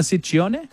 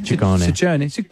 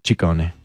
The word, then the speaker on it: Ciccone: Well, in Italian, it works the other way. So the double C's are hard C's and the singular C's are soft C's. – So Ciccone. – Ciccone.